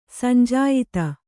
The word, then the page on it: ಸಂಜಾಯಿತ: sanjāyita; Kannada